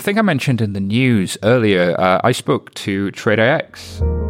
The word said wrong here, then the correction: TradeIX: I have the good fortune of being joined by the guy from TradeIX, as I now know it's pronounced.